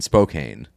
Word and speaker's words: Spokane: So it's but it but it, it kind of has to be pronounced that way because it's um uh cocaine flame in my bloodstream sold my coat when I hit Spokane. So like cocaine and in Spokane.